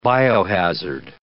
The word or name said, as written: biohazard